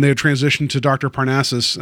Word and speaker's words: Parnassus: Well, I think one of the more interesting outputs of that is that Imaginarium of Dr. Parnassus,